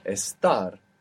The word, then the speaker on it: estar: Estar.